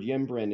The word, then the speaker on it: ymbren: which is spelled Y-M-B-R-E-N, ymbren.